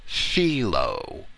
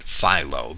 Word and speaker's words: Philo: And in Greek, that should really be pronounced Philo, but we'll pronounce it Philo because that's the popular pronunciation.